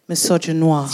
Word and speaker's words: misogynoir: So misogynoir. Have I said that right? I always. Yeah. Can you say it? – Misogynoir. – She says it so much better.